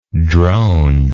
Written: dronish

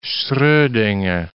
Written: Schrodinger

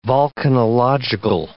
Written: volcanological

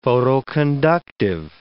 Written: photoconductive

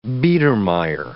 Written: Biedermeier

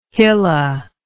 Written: hilla

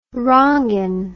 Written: ronggeng

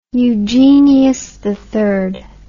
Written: eugenius iii

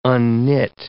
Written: unknit

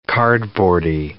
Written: cardboardy

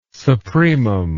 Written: supremum

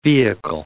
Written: vehicle

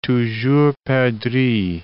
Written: toujours perdrix

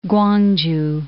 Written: Kwangju

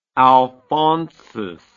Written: alphonsus

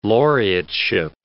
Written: laureateship

